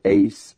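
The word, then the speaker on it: ace (εἰς): Now, the English word in, I-N, in the Greek is the preposition eis, E-I-S, and we pronounce it ace, A-C-E, okay?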